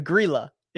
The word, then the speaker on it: Grillo: Emiliano, the the Grilla, the Grilla, if you want to pronounce it correctly.